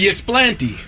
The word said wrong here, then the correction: Ypsilanti: We'll take Ypsilanti or Ypsilanti. I think the locals say Ypsilanti. Either way, it's not Ysplanty.